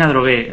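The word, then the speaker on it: Adrogué: Y es nacido en Androgué, no sé si lo pronuncio correctamente, provincia de Buenos Aires. – Sí, nací en Androgué.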